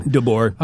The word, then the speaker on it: DeBoer: DeBoer? DeBauer? I don't know how to pronounce that guy's name. – DeBoer.